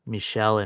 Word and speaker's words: Michel: Two guys named Michael that both pronounce it differently. Michelle and Mikhail.